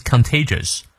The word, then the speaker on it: contagious: 注意它的重音，contagious。这个 just 注意别卷舌念成 jers 了啊。 But don't wait to catch it from others。 others 咬舌，be a carrier。好，从头到尾我们来过两遍。 A healthy attitude is contagious。